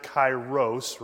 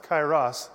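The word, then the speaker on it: Kairos (καιρός): And Kairos, if you're just joining us, now some people pronounce it, if you're English, right? If you speak English, you pronounce it Kairos, right? Chronos, Kairos. But it's actually a Greek word, and it is pronounced Kairos. Liz, am I right?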